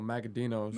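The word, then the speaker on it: Magaddino: Uh, the I might butcher this name because I'm not sure if there's a G or a D in it, but it's like Magafino to Todaro. So Magadinos. – Magadino.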